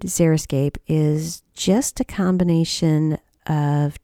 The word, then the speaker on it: xeriscape: Xeriscape is sometimes mispronounced as xeroscape, which to me sounds terrible.